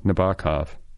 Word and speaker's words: Nabokov: I just learned how to pronounce his name, by the way, Nabokov.